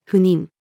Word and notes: 赴任: female

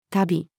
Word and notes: 度: female

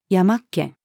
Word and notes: 山っ気: female

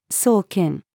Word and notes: 送検: female

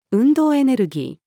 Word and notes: 運動エネルギー: female

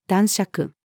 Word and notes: 男爵: female

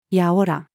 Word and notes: やおら: female

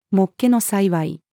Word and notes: もっけの幸い: female

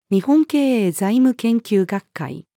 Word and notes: 日本経営財務研究学会: female

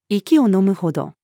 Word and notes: 息を呑むほど: female

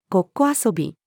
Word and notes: ごっこ遊び: female

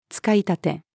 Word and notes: 使い立て: female

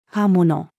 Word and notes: 刃物: female

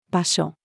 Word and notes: 場所: female

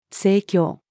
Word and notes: 正教: female

